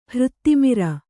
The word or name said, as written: ಹೃತ್ತಿಮಿರ